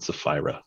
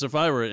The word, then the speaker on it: Sapphira: Ananias and Sapphira. – Okay, Ananias and Sapphira.